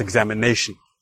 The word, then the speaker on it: examination: All right, so I hope you can hear the difference in that the fourth syllable, the ne, is where we hear the stress, examination.